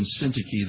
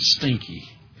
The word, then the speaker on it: Syntyche: And sometimes I mispronounce their names and say, "I beseech Odious and Stinky that they be of the same mind."